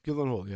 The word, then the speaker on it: Gyllenhaal: Now, this stars, I always struggle to pronounce his name, Jake, is it Gyllenhaal? Jake Gyllenhaal? – Gyllenhaal, yeah.